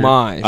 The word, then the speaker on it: homage: That might be a homage to Back to the Future. – Homage. – A homage. – Homage. – Homage. – A homage. – No, you don't pronounce. You don't have to pronounce the H.